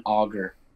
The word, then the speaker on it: Auger: Auger is actually the French pronunciation. So growing up, I heard a lot of people, especially refs from, you know, Montreal area and stuff, saying Auger, but yeah, Auger. Auger's right.